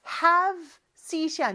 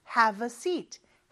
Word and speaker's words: have a seat: 그리고 발음할 때는, a가 have에 묻혀서 잘안 들린다고 했죠. have seat이 아니고, have a seat. have a seat. 살짝 어가 들어갑니다.